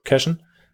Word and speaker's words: Cashen: Und gerade Cations, spreche ich das richtig aus? Oder wie würdest du das aussprechen, den Namen? Mason Cashen, ja. Cashen, wurde berühmt für folgende Ausdrücke.